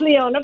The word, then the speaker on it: Leona: As we roll on Tech Talk with Alan and Kyle and Brian and Leanna on the phone. Did I pronounce that correctly, Leanna? – It's Leona, but that's Leona, fine.